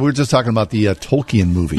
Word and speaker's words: Tolkien: And he corrects the professor and says, Tolkien.